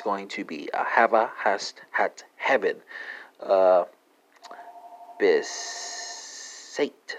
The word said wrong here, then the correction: bestätigt: spelled B-E-S-T-A-T-I-G-T, or bestätigt. Maybe that's a better pronunciation. Bestätigt, yeah. Spelling's right, though.